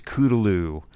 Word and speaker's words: Cthulhu: I hear the commentary track from an episode of the new Twilight Zone written by Harlan Ellison, where Harlan Ellison goes off about how the correct pronunciation is "kudaloo," And I'm like, good for you, Harlan Ellison, but nobody else says that.